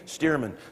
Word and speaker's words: Stearman: "Stearman." "Spell it for me." S-T-E-A-R.